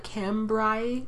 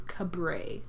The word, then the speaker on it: Cambrai: It looks like Cambrai or Cambrai, but it when I clicked on it to hear the pronunciation, it was Cabray.